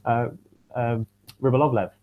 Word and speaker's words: Rybolovlev: I mean, they had Rybolovlev. Have I said his name right? – Yes.